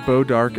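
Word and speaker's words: Bois d'Arc: So that's Bo Dark, and we should emphasize that is spelled BOISD'ARC. Kind of a tricky name there.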